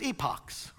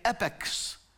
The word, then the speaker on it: epochs: And they actually said in America, you say epochs.